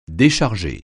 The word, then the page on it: décharger: French